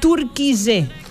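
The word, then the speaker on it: Türkiye: En 2022, la ONU reconoció el cambio de nombre a, se pronuncia así, Turquille. – Bien. – La solicitud se hizo para que el nombre inglés Turkey dejara de estar asociado al pavo. – Al pavo, al turkey, claro. – Entonces, ahora para que ustedes lo pronuncien menos, se llama más Turquía. Lo tienen que pronunciar Turquille. O sea, Turquille. Españolizado es Turquille.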